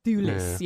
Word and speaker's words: Thewlis: But I there was just how do you say that guy's name? Is it David Thewlis? – Thewlis, yeah. Yes.